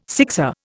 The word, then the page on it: sixer: Indian